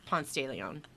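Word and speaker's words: Ponce de León: No, that's not how you say it. It's Ponce de Leon, because that's not French, it's Spanish.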